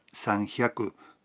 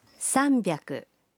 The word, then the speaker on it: sanbyaku: 300 no se pronuncia sanhyaku, sino sanbyaku.